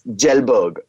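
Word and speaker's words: Kjellberg: Now PewDiePie is a Swedish YouTuber called Felix Jellberg. Okay, there's a silent K in there.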